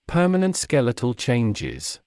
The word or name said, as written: permanent skeletal changes